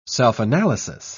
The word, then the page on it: self-analysis: British English